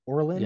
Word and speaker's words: Orlen: So this is Orel or or Orel Orlen. I can never pronounce the name. It's an oil company. It's the chief sponsor, Orlen. Yeah, or- Orlen. Orlen. Orlen. Orlen. Thank you.